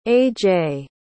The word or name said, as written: aj